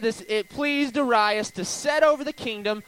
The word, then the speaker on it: Darius: And I learned this week through YouVersion Bible app that I've been saying this name wrong. I've always said Darius. You know what I mean? Does it look like Darius to you? But as I was going through YouVersion Bible app, it says Darius.